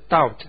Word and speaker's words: doubt: 怀疑啊，英文就是 doubt。 D-O-U-B-T 这个 B 不发音 Doubt。